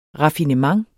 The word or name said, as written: RAFFINEMENTERNES